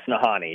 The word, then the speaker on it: Nahanni: Is it Nahanni Valley? – Yes, Nahanni. Yeah. – Nahanni. – I mess it up too sometimes. But yeah, I always try to think like Han Solo.